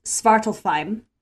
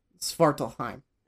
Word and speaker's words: Svartalfheim: After defeating the Dark Elves, and I'm gonna butcher this, I believe it's pronounced Svartalfheim. – Svartalfheim. – Svartalfheim.